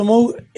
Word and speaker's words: Moog: Hay algo muy lindo en el arco de Moog, digamos Moog, pronunciamos como pronuncian ellos, si bien que.